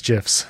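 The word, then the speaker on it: GIF: Don't you dare come at me for that, soft G. Don't you dare. – Yeah, it's Christmas GIFs. That's fun. I don't know why this has never come up. We're on episode 48 of this podcast, and I've never heard you say that word out loud. But now... – Because that's how it's pronounced. The man who created it says it's that way. That's how it is.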